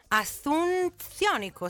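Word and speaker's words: Asunciónico: percorsa per raggiungere il festival musicale Asunzionico. No, Asunzionico, sì, l'ho detto giusto al primo colpo.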